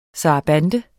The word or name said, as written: sarabande